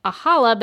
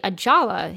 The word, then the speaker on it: Ajala: I've been saying Ajala, but it's actually Ajala.